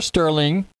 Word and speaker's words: Stirling: Okay, well, I want to thank my guest this evening, my very special guest, Sir Stirling.